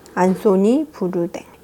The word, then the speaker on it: Anthony Bourdain: 어, 여기서는 앤토니 보데인이라고 얘기를 하더라고요. 근데 한국 표기로는 안소니 부르댕.